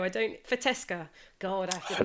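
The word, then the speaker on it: Fetească: Fetele, and the variety is called Fetesca. Fetesca regala, yeah. – Oh, it's not Fetiashka. – No.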